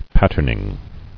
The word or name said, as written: Patterning